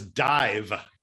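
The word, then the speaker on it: Dàimh: It says dive. The MH in Gaelic is pronounced like a V in English, and it's the name of a Scottish band that I'm a big fan of, have gone to festivals to see.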